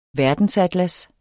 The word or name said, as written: verdensatlas